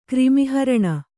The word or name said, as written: ಕ್ರಿಮಿಹರಣ